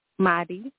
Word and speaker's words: Maadi: This is Maddie from Cleveland, Ohio. – Okay, greetings to you. How do you say your name? – Maddie. – Maddie. – Yes. – Maddie. M-A-D-D-Y? – M-A-A-D-I.